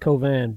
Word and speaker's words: Cauvin: C-A-U-V-I-N. It's not spelled the way it sounds. You pronounce it Covan, but it's C-A-U-V-I-N.